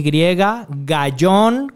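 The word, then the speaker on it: Gayón: Arroba Moy Gallón, así como se escribe, así como siempre lo has visto. Moy con Y, Gallón con Y, G-A-Y-O-N.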